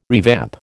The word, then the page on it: revamp: American English